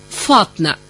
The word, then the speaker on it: فاطمة: وفما زيد شكون يسميهم بنتاتق بالنون، وهذا على حسب نطق كل جهة في قرقنا. كما مثلا اسم فاطمة، فما اللي يقول فاطنة.